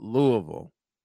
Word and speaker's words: Louisville: Who the hell thought Louisville? Yes, I'm saying it right.